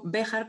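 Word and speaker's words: Béjar: Ah, Beatriz Paniego Bejar, por cierto, con el acento en la E,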